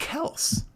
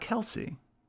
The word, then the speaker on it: Kelce: He said on a show today that it's pronounced Kelce, not Kelce.